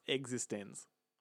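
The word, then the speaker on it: eXistenZ: And just for the record, as I mentioned earlier in the show, it's pronounced existens. – Existens. – And the word itself is lowercase e, capital X, lowercase isten, uppercase Z. So it's existens, emphasis.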